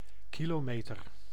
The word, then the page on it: kilomètre: France; [æ̃ ki.lo.mɛtʁ̥]; Paris